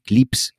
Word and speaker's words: CLPS: Y la NASA hace unos años creó un programa que se llama CLPS, CLIPS, lo pronuncian ellos, que es básicamente intentar recrear el éxito de los programas comerciales la ISS, de las naves comerciales, pero con estas sondas.